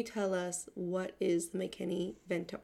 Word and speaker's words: McKinney-Vento: McKinney-Vento? – McKinney-Vento, yeah.